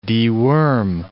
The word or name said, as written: deworm